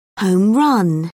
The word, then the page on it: home run: British English